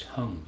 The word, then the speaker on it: Hang: And it's also called a hung, but it's spelled H-A-N-G. Pronounced hung for some reason that I don't fully understand.